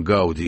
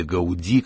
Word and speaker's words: Гауди: Андрей, как вам кажется, сам Гауди или Гауди, как иногда делают ударение в его фамилии, сам Гауди захотел бы видеть свой собор достроенным?